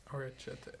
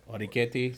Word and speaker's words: orecchiette: Orchette al funghi. Fungi. – Orecchiette. Orecchiette. So orecchiette al fungi.